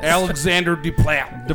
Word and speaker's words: Alexandre Desplat: Alexandre Desplat.